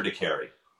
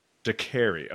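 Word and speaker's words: Decarie: Hector Dakarry. – Dakarry. Okay. I think I pronounced it like Dakar.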